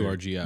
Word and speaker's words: URGI: It's one of their nice, is it URGI, or do you pronounce the letter? – URGI.